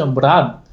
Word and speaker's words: 蛮: 这个蛮字呢，我们现在的一种看法呢是认认为这个蛮的古代读音是 mlan，